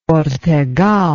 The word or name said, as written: ortegal